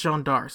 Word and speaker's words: Jeanne d'Arc: It's pronounced Jeanne d'Arc, so let's go with that.